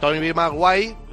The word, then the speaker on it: Tobey Maguire: a Tobey Maguire, no sé si lo he pronunciado bien, sí, muy bien.